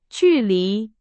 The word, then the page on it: 距离: Chinese